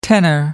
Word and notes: tenner: American English

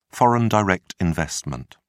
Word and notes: foreign direct investment: British English

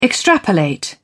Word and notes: extrapolate: British English

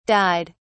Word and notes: died: British English

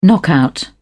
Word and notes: knockout: British English

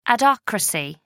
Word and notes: adhocracy: American English

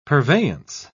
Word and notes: purveyance: British English

